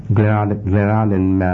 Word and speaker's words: Glenarden: G-L-E-N. – A-R-D-E-N. – A-R-D-E-N. Glenarden. – Yeah, Maryland. – Glenarden, Maryland.